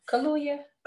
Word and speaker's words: Kaluuya: Daniel Kaluuya. Uh-huh. I mess up his last name all the time. – Kaluuya. That's how you say it.